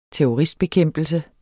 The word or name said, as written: terrorist